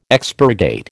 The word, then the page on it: expurgate: American English